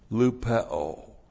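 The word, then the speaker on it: lupeo (λυπέω): So, for behold, what this very thing, this, that is, this according to the will of God, lupeo. And that's also repeated. L-U-P-E long O, lupeo.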